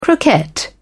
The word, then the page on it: croquette: British English